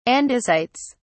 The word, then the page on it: andesites: British English